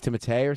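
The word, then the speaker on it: Timothée: It's Timothy Chalamet. – It's not Timothy, it's Timotei or something, because it's Chalamet, Timotei.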